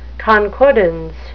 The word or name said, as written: concordance